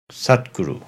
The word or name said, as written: Sat-Guru